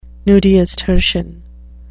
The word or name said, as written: nudiustertian